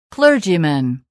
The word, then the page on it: clergyman: klə́ːrdʒimən